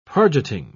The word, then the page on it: pargeting: British English